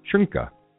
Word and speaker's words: Schoenke: Oh, Sean Pacino is writing me on how to properly pronounce Aaron Schnocky's last name. Schoenke? Am I right, Sean? Schoenke? All right, boys and girls. Thank you very much for joining us today. I'm gonna pronounce it the way that Sean's asking me to pronounce it.